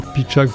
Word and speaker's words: Pitchak: Pitchak France Solidaire, qui est le nom de l'association en fait. – Donc ça s'écrit comme ça se prononce. – Voilà, comme ça se prononce, P-I-T-C-H-A-K.